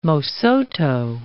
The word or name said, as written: Mosotho